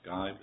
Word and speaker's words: Guibe: Is that pronounced Guibe? Is that right? – That's correct, Your Honor.